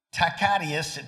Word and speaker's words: Tacitus: There are Roman historians, I'll see if I can pronounce their name right, Tacitus, T-A-C-I-T-U-S,